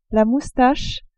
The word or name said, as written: moustache